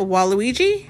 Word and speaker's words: Waluigi: Waluigi? – Mm-hmm.